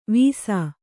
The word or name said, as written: ವಿಸ